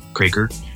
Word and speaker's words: Kroeker: Uh, I will be posting on Twitter when I can, and my handle on both of those accounts is just Jeremy underscore Kroeker, and my name doesn't sound the way it is spelled, so you have to look it up.